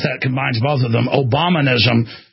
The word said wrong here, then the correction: Obamunism: How do you say it? Obamianism? Obamianism. Obamianism. No, that's not right. Obamunism. Sometimes I have trouble with a word. It's Obamunism.